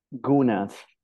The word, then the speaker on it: Guna: Gunas. – Yeah. – The Gunas, they used to be known as Kunas. Now, correct pronunciation is Guna.